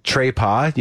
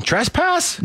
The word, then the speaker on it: trespass: Trepas? You mean trespass? – Yeah. Trespass. – Trespass. – It's trespassing.